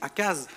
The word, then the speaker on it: Achaz: Achaz, ça s'écrit Achaz, mais ça se dit Achaz normalement.